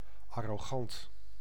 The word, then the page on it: arrogant: American English; /ˈæɹəɡənt/